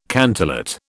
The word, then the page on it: cantillate: British English